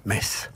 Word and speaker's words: Metz: On retrouve ça dans plusieurs villes en France, et ça se prononce bien. Metz.